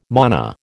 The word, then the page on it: mana: American English